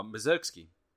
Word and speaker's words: Mussorgsky: You are Mussorgsky.